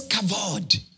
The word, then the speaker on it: kavod: kavod. Kavod is spelled K-A-V-O-D. K-A-V-O-D.